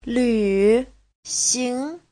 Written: lv3xing2

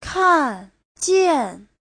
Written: kan4jian4

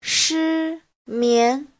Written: shi1mian2

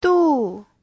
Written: du4

